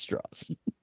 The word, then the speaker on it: Strauss: And loudly in my own home, I said Straws. So, because that's also, he could also correct people in the movie. He's like, it's pronounced Straws.